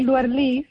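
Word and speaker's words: Luer Lís: Y le puso, me puso mi Luer Lis y lleva un acento en la I.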